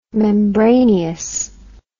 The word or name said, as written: membraneous